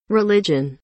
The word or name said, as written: religion